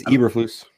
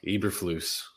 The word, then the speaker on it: Eberflus: I don't know if that's pronounced right. – I think it's Eberflus. – Eberflus. That sounds much better.